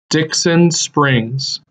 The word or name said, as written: Dixon Springs